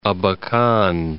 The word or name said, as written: Abakan